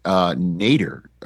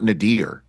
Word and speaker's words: nadir: Point number two is I say Nader, not Nadir. That's the way I pronounce that word.